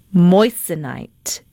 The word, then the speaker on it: Moissanite: Moissanite. Am I saying that correctly? – Oh, yeah.